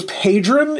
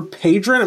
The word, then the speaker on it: Padron: His name was Padron, is the way you say his last name. So it's Nicholas Christopher Padron. It may be Padron, P-A-D-R-O-N, is how you say his last name.